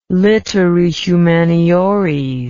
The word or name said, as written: litterae humaniores